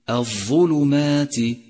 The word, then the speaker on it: الظلمات: ثانيا، راقب حركة فمك وأنت تقرأ كلمة الظلمات، الظلمات، فالظاء مضمومة تضم الشفاه عند نطقها، والميم مفتوحة تفتح الشفاه عند نطقها، والتاء مكسورة يخفض الفم عند نطقها، وهذا ما يسمى إتمام الحركات، تتناسب فيه حركة الشفاه مع حركات الحروف.